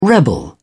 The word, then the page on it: rebel: British English